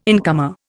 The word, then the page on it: incomer: Indian